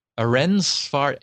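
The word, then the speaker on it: Ehrensvärd: Stina Ensvard, I think is how I would pronounce it. Ehrensvard, Ehrensvard.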